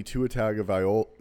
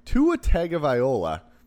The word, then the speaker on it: Tagovailoa: Tua Tagovailoa. I said that wrong. Tua Tagovailoa. There we go.